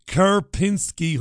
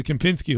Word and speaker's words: Kempinski: Been listening to you since you used to broadcast from the Kerpinski Hotel. And then in brackets he says, probably butchered that name. Yes, you did, Kenny. It's the Kempinski Hotel in Marbella.